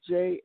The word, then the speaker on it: Jamesina: First of all, social media, you can reach me on Facebook, Jamesina Green, that's spelled Jamesina. It's James with I-N-A on the end of it, and the last name is Green with the E.